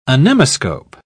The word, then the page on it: anemoscope: British English